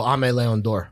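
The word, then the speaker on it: Aimé Leon Dore: How do you pronounce the is it AIM or AME? – Oh, AME Leon Dor.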